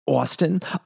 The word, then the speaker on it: ASTN: His stage name is capital A-S-T-N. But pronounced Austin.